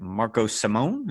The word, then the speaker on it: Marco Simone: I guess we'll find out in a couple of weeks when they play the Italian Open at Marco Simone. Is that how you say it? – Yes, well done.